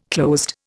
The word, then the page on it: closed: Indian